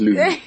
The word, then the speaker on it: Looom: L-O-O-O-M. – How do you pronounce three L's? – I thought it was... – Loom. Loom. Loom. I think it's just Loom.